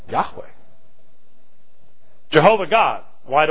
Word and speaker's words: Yahweh: And the best pronunciation is Yahweh.